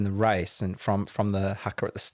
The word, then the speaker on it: haka: And so coming into the end, having that, I didn't know they did the haka, I think is how you say it.